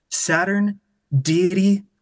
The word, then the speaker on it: Saturniidae: It should say on the Bandcamp and the Instagram, pretty much anywhere, it's pronounced Saturn Deity, but you drop the D, so it's Saturn Deity.